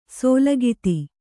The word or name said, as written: ಸೂಲಗಿತಿ